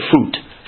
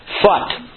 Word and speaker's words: Phut: I used to say Phut. Phut.